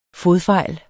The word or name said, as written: fodfejl